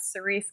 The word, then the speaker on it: Cerise: I'm at Cerise Castle.